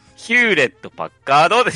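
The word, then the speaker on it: Hewlett-Packard: ヒューレット・パッカードですよ。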